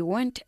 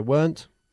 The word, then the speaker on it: weren't: We weren't hungry. – ¿Cómo se pronuncia? Weren't.